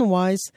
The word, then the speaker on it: Weiss: Mais hier soir, alors que défilaient les Emmys plutôt tranquilles, il y a un réalisateur, Glenn Weiss, W-E-I-S-S pour bien le prononcer, qui venait de monter sur scène pour recevoir un Emmy pour un Variety Special.